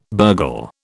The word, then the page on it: burgle: British English